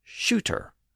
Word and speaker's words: suitor: So within these back-to-back scenes, we have clear indications that Sue was sometimes pronounced as shoe, and suitor was sometimes pronounced as shooter.